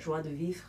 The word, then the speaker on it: joie de vivre: Joie de vivre? – Yeah.